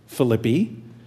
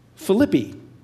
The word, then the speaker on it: Philippi: Now, I say Philippi because when we visited Philippi, we were instructed that it is pronounced Philippi instead of Philippi, which I thought sounded so much more dignified. But it is Philippi.